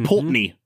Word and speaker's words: Pulteney: Old Pulteney, which I had to look up how to pronounce because there is an E in there that I didn't know if it would be pronounced kind of like the Balvenie, like if it's going to be Pulteney. It's just Pulteney.